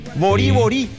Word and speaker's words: bori bori: Pero eso es por una cuestión de que el bori bori está escrito en guaraní. Por eso es que se escribe con B corta, porque en guaraní la B larga no existe. Y cuando la palabra se acentúa en la última sílaba, no lleva tilde gráfica en guaraní. Entonces, si vos lo lees en castellano, es bori bori. Pero en realidad hay que leerlo en guaraní, bori bori.